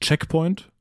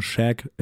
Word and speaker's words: Shag Point: Nicht Checkpoint, ich habe das nicht falsch ausgesprochen, sondern Shag, S-H-A-G.